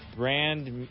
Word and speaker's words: Brandmeier: This is Colin Jost, and you're listening to Brandmeier. I think I'm pronouncing that correctly. My German heritage is failing me. – That is correct, Colin Jost.